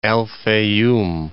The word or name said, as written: Al Fayyam